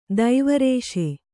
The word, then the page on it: ದೈವರೇಷೆ: daiva rēṣe